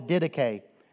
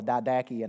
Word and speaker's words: Didache: It's called the Didache. I didn't know that's how you pronounce it for the longest time. I thought it was called the Didache, and I made myself like an idiot when I would say that.